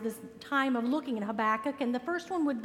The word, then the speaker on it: Habakkuk: So I said, first of all, I've had a cat that had problems with fur balls, and so when he'd cough it up, Habakkuk is what it sounded like to me.